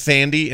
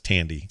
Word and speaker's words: Thandie: I always thought it was pronounced Thandy, and it's not. It's Tandy. – Tandy, right? – Yeah, it's T H, but they pronounce it Tandy.